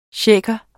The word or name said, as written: shaker